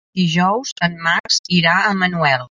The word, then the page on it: Manuel: catala